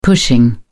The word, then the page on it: pushing: British English